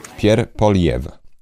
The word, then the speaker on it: Pierre Poilievre: Pierre Poilievre. Źle wcześniej wymawiałem jego nazwisko.